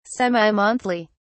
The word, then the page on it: semi-monthly: British English